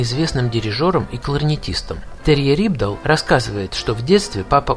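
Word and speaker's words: Rypdal: И трудно привести пример музыканта, который уже после этого так свободно трансформировал бы гитарный звук, наполняя его неповторимыми нюансами и интонациями, как Терье Рибдал. Будем так произносить его фамилию, хотя сами норвежцы, соотечественники музыканта, умудряются выдать нечто среднее между Рёбдаль, Рибдаль и Рюбдаль.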